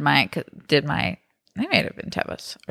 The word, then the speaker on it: Tevas: But Pickle chewed my, I call them Tevas. I know they're pronounced Tevas, but my tried and trues.